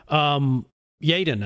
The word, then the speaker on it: Yeadon: Yadon, I should say. Y-E-A-D-O-N.